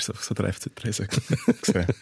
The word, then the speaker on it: Triesen: Äh, wie sagt man? Tresen. Entschuldigung. Das kann man nicht lernen. Wir, wir werden noch ganz viel falsch machen heute und falsch aussprechen. Tresen.